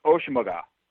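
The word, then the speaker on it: Asomugha: Now you say, well, they got Nambi, and I believe it is pronounced Oshimaga.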